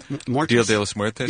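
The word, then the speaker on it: Día de los Muertos: What got you into, is it, how do you say it, Dia de los Muertos? – Dia de los Muertos? – Yes.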